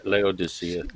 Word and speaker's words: Laodicea: And are the warnings to Sardis and Laodicea meant for professing Christians in the last days? Yeah, I know I messed that one up. Go ahead. Go ahead. – Laodicea.